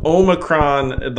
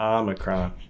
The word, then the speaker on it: Omicron: Dude, so Omicron, the long O is not right. It's just odd. Just make an AH, Omicron.